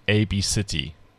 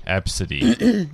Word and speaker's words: ABCDE: But it's pronounced A-B-City. – Ab-City. – Ab-City. – Yeah. – Okay. – So it's it's a bit of a trend in the U.S. now. It's Ab-City, spelt A-B-C-D-E. – A-B-C-D-E Redford, Ab-City.